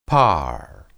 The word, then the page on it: par: hebrew